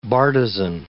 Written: bartizan

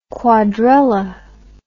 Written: quadrella

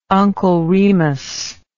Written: Uncle Remus

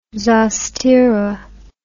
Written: zostera

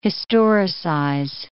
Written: historicize

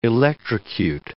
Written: electrocute

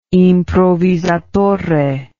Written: improvvisatore

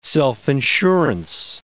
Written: self-insurance

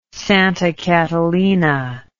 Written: Santa Catalina

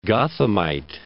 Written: Gothamite

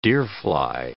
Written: deerfly

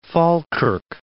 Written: Falkirk

